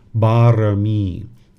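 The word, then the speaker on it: barami (บารมี): It's pronounced barami.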